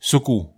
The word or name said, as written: seku